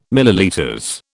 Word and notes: ml: British English

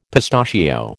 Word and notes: pistachio: American English

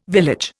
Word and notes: village: Indian

